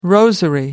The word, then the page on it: rosary: American English